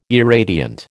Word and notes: irradiant: American English

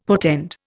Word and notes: portend: Indian

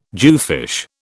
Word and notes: jewfish: British English

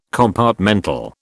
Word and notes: compartmental: British English

